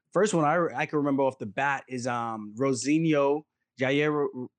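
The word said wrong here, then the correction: Jairzinho Rozenstruik: I always call him Jair. Is it Jair? – It's Jairzinho Rozenstrike.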